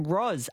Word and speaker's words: Roz: This text, I had an issue frequently in the States – as a last straw, I would spell out my name, Roz, R-O-Z. And then I had an order for Rosette. overlooked that they pronounced Z as Z.